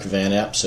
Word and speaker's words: Van Epps: Yep, my name is Mark Van Epps. It's Mark, M-A-R-K. Van Epps is V-A-N, capital E-P-P-S.